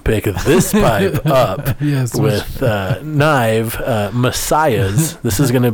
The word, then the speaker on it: Gnyve: In Knive, we spell it all weird because we're from the new metal generation, so it's spelled G-N-Y-V-E, pronounced Knive.